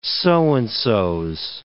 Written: so-and-so's

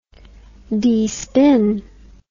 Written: despin

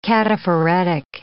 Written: cataphoretic

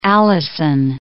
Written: allicin

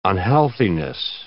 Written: unhealthiness

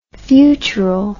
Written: futural

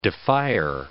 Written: defier

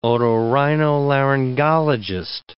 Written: otorhinolaryngologist

